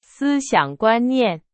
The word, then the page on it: 思想: Chinese